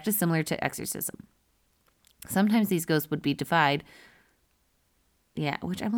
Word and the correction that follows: deified: I don't know if I typed that right. Deified. There we go. I think I've just been saying it wrong this whole time.